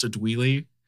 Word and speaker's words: Adele: I considered putting in as a top story idea Adele changing her name, or not changing her name, but like clarifying that her name is pronounced a Dweely.